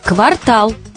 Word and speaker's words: квартал: В русском языке только такое ударение «квартал» соответствует норме.